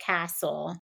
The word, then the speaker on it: CASEL: So if you look at, I pronounce it CASEL, C-A-S-E-L.